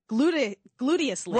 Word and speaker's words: gluteously: Glutinously proficient persons. – Glutinously proficient. – Right? Excuse me. I said that wrong. Not glutinously. Gluteously.